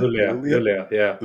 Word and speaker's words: Luleå: And by the way, I'm not even sure if I pronounced that name correctly when I did the intro. Luleå? – Luleå. – Luleå. Okay, gotcha.